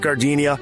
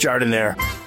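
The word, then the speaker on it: giardiniera: And guys, when you're at the counter, don't call it Gardenia. It's pronounced Jardinere.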